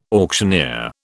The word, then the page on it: auctioneer: British English